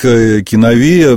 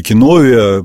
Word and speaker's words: киновия: А так, киновия, ну, или киновия, по-разному произносят это греческое слово, в дословном переводе это общее житие, общая жизнь.